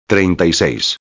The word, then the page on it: 36: espanhol